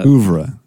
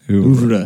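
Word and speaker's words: oeuvre: How are you supposed to say ouvre? You're not supposed to say it like that. – Ouvre, right. – Ouvre. There we go.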